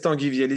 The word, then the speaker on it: Tanguy Viel: And then in The Innocent, you worked with, am I saying his name right, Tanguy Viel? – Yes, Tanguy Viel is a writer.